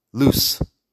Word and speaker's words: loose: La diferencia, en cambio, es con la pronunciación de la S. Escúchalo bien. – Lose, de perder, loose, que es flojo o suelto.